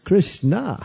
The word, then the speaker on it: Krishna: It's not Snika, Snika, Snika, Snika, Rum, Rum, Rum. It's Krishna. So pronunciation is very important.